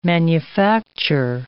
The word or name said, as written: manufacture